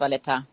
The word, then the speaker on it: Valletta: Hauptstadt Valletta, ist das richtig ausgesprochen? – Richtig, genau, unsere Hauptstadt ist Valletta.